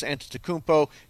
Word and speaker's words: Antetokounmpo: Great night last night for Giannis Antetokounmpo.